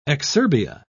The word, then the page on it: exurbia: British English